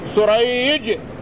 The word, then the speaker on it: سريج: والثاني بالسين المهملة والجيم سريج، وهو من شيوخ البخاري رحمه الله، فهو النوع الذي يقال له المتشابه.